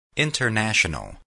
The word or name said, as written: international